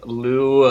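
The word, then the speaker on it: Liu: Does it have, I feel like it has a Z in the what? Name. It's like one of those. – Like L-I-U-C-I-X-I-N.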